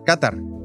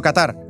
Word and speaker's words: Qatar: Quizá sorprenderá que lo pronuncie así, Qatar, y no Qatar. Pero es que así es como se pronuncia en árabe, me lo explican los profesores universitarios Ignacio Álvarez Osorio e Ignacio Gutiérrez, autores del libro Qatar, la perla del Golfo.